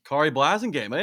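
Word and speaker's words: Khari Blasingame: This is Sode number 41, the Carey Blasingame? – Kari Blasingame. Hey, there's a Kari. – Oh, gosh. Oh, geez. Here I am to hosting a Titans podcast, and I just mispronounced his name. Kari Blasingame.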